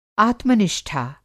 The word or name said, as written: आत्मनिष्ठा